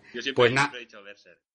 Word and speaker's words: Berserk: Y bueno, y, hablando de, Berserk, por cierto, la, correcta pronunciación en inglés es Berserk.